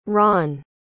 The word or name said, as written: ron